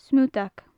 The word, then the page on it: smutag: gaelic